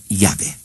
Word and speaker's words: Yahvé: Porque los samaritanos, que nunca dejaron de pronunciar el nombre de Dios, siempre han dicho Yahvé.